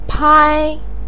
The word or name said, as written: pai1